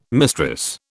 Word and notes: mistress: British English

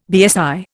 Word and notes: BSI: Indian

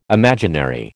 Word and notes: imaginary: American English

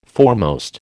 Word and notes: foremost: American English

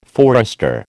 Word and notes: forester: American English

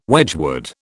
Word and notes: Wedgwood: British English

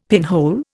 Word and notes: pinhole: Indian

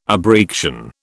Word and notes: abreaction: British English

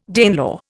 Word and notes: Danelaw: Indian

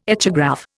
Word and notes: echograph: Indian

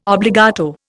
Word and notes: obbligato: Indian

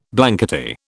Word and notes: blankety: British English

